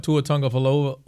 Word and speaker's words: Tua Tagovailoa: So do I want Tua Tagovailoa or – great pronunciation on that, by the way.